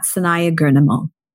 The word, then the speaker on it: Sanaiyah Gurnamal: Tag at Project Loving Myself Podcast and tag me too at Sanaya Gurnamal. Sanaya is spelled S-A-N-A-I-Y-A-H-G-U-R-N-A-M-A-L.